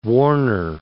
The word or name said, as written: warner